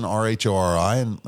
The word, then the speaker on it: Rhori: Way up in the family tree, there's an Rhori.